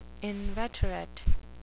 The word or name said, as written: inveterate